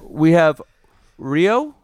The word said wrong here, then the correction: Riho: Is that how you say her name? – Riho? – Yep. Riho and Hikaru Shida? – Hikaru Shida.